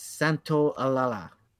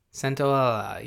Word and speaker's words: Santaolalla: I would say it's Santaolalla. – Santaolalla, yeah.